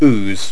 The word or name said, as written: ooze